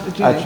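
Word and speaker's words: Adjaye: The architect was David Ajay. Pronouncing it right? – Ajay.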